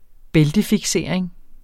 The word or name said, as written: bæltefiksering